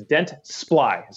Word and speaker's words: Dentsply: It's Dentsply. – So Dentsply. – It's two syllables,